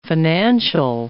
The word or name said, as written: financial